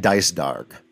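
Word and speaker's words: Dycedarg: So Ramza's brothers, Zalbog and Dice Darg. That's how I'm pronouncing the names? – Me too.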